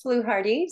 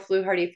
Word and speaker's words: Fluharty: So I'm Rose Fluharty. So Rose, and then I'll spell Fluharty for you.